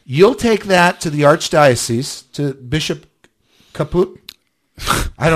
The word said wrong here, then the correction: Chaput: Do you spell it? – Chaput. – Caput. – No. – Okay. No. Anyway, Chaput.